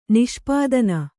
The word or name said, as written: ನಿಷ್ಪಾದನ